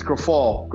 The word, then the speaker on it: Grifol: So Grifol, is that how you say it? Grifol? – It's Grifol. Grifol. – Grifol. Like Grifol.